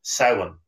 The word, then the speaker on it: Samhain: And it originated with the ancient Celtic festival, Samhain, pronounced S-O-W-W-I-N.